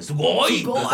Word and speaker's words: sugoi: Me gusta mucho, aparte me gusta mucho la palabra sugoi, que, siento que la tienes que pronunciar con la pronunciación japonesa como de sugoi.